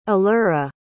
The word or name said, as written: allura